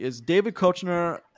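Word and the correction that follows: Koechner: and I don't think I'm saying that right. – Kechner. – Oh, Kechner. So David Kechner and Kristen Chenoweth both playing outside normal type, which I love.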